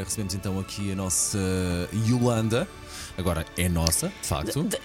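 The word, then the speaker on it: iolanda: É Yolanda e o I é minúsculo. – Ok. – Por isso, é a nossa vencedora do Festival da Canção, temos de decorar como é que se escreve e como é que se diz.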